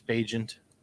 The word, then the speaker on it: Bagent: For me, is that how they say his name, Bagent? – Yeah, it's Bagent. That's how I've heard the announcers pronounce it and other people pronounce it. – Did anyone Bagent, call him T-Bag? – Bagent, like the secret agent.